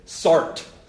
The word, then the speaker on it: Sartre: Those are the arguments of people that believe in something that this philosopher came up with named Sartre. Or as we Americans say, Sartre.